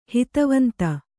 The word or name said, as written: ಹಿತವಂತೆ